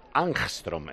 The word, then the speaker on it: Angstrom: El Armstrong, estoy aprendiendo hoy, ¿eh? Angstrom, ¿eh? Se escribe Angstrom. – A-N-G.